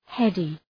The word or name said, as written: heady